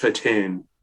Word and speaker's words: Titane: I think it's Titane. I worked at a movie theater and I missed it when it was there. But we had like so many people coming in being like, yes, it's pronounced Titane.